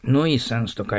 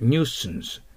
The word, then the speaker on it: nuisance: ノイサンスと書いてありますけど、発音記号を見てください。はい。ニューサンス。